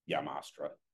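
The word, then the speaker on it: Jamastrán: I mean, the Honduran Jamastra, like, or Yamastra. That's how it should be pronounced, but whatever.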